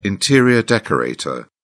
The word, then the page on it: interior decorator: British English